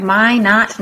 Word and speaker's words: Minot: So Minot, North Dakota. Did I say it right? – Yes, that is correct. Minot, North Dakota.